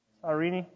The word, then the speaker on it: Cyrene: Cyrene.